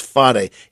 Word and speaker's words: Phare: We go to we go one night to the Phare Circus, P H A R E, pronounced Fade.